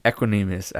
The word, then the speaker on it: Equanimeous: And, okay, so Equanimeous after practice.